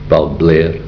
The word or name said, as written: BALBLAIR